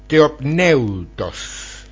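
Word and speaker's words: theopneustos: Porque esta palabra aquí es la palabra theoneptous, lo quiero pronunciar bien, theoneptous, theopneutos, y significa el aliento divino, y esto constituye lo más o la más importante declaración de la escritura acerca de sí misma.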